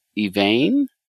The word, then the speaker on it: Yvain: Spelled Y-V-A-I-N. Yvain? – Yeah, you could pronounce it Yvain or Yvain.